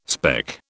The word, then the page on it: speck: British English